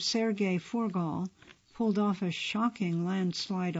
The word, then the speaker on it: Furgal: Forgal is spelled F-U-R-G-A-L.